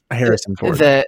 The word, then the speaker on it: Harrison Ford: Harrison Horde? – Did I say Harrison Horde? – Harrison Ford.